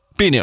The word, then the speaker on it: Bennu: Bennu? B-E-N-N-U. How would you say that? Bennu? Yeah. Bennu. I'm going to say Bennu. I like that better.